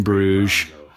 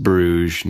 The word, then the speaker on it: Bruges: All right, we just screened In Bruges, pronounced Bruges, not Bruges.